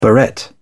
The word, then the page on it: barrette: British English